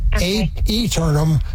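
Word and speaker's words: Aeternum: The A, it's A-E-T-E-R-N-U-M. It's said Eternum, but it's spelled A-E-Ternum.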